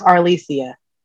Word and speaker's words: Arlesia: Hi, and my middle name is pronounced Alessia.